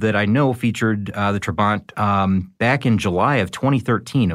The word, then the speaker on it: Trabant: Now, the Trabant, and I've heard that pronounced a couple different ways. I say Trabant.